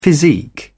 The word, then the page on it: physique: British English